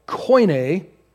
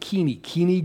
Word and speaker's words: Koine: And this level of Greek was called Koine Greek, Koine Greek. Today in Greece, they pronounce it Kini Greek, okay?